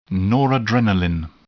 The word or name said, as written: noradrenaline